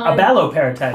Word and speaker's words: abaloparatide: Abaloparatide.